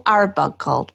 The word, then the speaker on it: Arbuckle: And today I have a very exciting guest, Rachel Arbuckle.